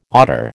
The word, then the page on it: otter: American English